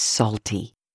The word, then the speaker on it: Psalty: But way back in the beginning was Psalty. That's with a silent P like Psalms.